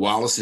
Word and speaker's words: Wallace: And Wallace is W-A-L-L-A-C-E.